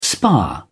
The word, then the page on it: spa: British English